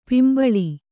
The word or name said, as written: ಪಿಂಬಳಿ